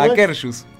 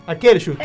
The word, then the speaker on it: Akershus: El Ashke Ah, Akershus. El en, en Epcot también, claro. Ah, ahí la, conocí. Eh, conocí. Eh, eh, ¿Cómo vamos a volver a pronunciar? – ¿Cómo Akershus. – Akershus.